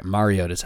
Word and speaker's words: Mariota: and then you're right, Mariota's how it's pronounced.